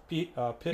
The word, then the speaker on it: Pitd: they can go to the, how do you say the name of the, is it Pitted RC or Pit? Yeah, P-I-T. It stands for playing in the dirt.